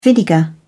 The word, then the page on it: vinegar: British English